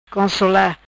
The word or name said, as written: Consola